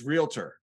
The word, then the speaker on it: realtor: And by the way, it's realtor, two syllables, not three. A lot of people that I hear them say realtor, think actor or actress, actor, realtor, same type of cadence there.